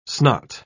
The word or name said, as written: snot